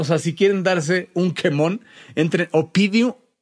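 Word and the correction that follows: Oppidum: Opidum, se, se escribe con doble P, Opidum, así como se oye.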